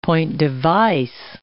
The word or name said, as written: point-device